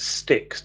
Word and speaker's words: Styx: Your pronunciation threw me slightly there because I would pronounce it sticks to rhyme with nicks.